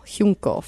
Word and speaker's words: Huincul: Huincul. – Huincul formation.